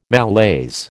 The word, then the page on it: malaise: American English